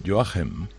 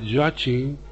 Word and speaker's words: Joachim: Bueno, debo decir que se dice Joachim. – Yo esta mañana he hecho alguna prueba de sonido para ver cómo se pronuncia en alemán y es Joachim. Dicen Joachim.